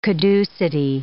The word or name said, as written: caducity